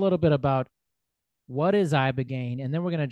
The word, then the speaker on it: Ibogaine: Yeah, Ibogaine.